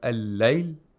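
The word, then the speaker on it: الليل: And also the lam is not pronounced, just like the previous word. We have al-layl and an-nahar.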